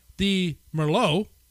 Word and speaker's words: Merlot: The Merlot, little silent T on the end of it there, had a metallic aftertaste.